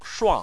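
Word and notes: shuang4: tone 4

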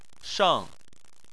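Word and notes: sheng: tone 4

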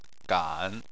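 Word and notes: gan: tone 3

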